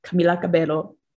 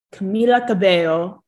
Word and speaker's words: Camila Cabello: So it is Camila Cabello. Camila Cabello.